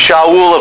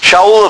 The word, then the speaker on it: Shaul: named Shaul of Tarsus. I'm pronouncing it from the Hebrew. Shaul of Tarsus,